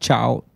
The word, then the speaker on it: chao: Or Spanish speakers, we just spell it C-H-A-O versus C-I-O-A.